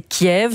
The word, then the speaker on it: Kiev: La prononciation, c'est Kiev. C'est aussi comme ça sur la VRT. C'est comme si, aussi comme ça à la SDF.